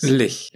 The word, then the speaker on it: Lich: Spricht man das Lich oder Lich aus? Lich. – Lich.